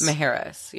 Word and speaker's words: Mujeres: Wait, is that how you pronounce it? Mujeres? – Mujeres. Yeah. Oh, Mujeres. Isla Mujeres.